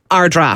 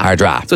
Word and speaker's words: Ardara: It's Ard, yeah. So it's spelt A-R-D-A-R-A. – Which is Ardara. – Ardara. – Yeah. – But it's pronounced Ardra. – Ardra. – So it's Ardra.